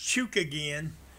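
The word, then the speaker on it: Chookagian: Versus Caitlin Kukajian. We'll start with Fat Man on Fights. – I think it's pronounced Chook again.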